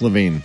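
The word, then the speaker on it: LaVine: Well, maybe they figure, is it pronounced Levine, right? Maybe they figure Levine. – Yeah, I said. – For the rescue. – Yeah, Zach Levine.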